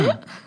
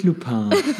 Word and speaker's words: Lupin: Entschuldigung, ich habe es falsch ausgesprochen. Es Lupin. heißt Lupin. – Nicht Lupin.